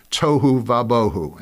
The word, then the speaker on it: tohu vabohu: So the Hebrew word translated without form and void are the rhyming words tohu vabohu, and that's how it's pronounced.